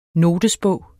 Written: NOTESBOGENS

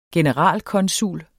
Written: generalkonsul

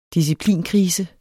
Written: disciplinkrise

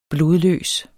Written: blodløs

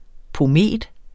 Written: pomet

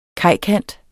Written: kajkant

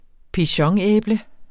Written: pigeonæble

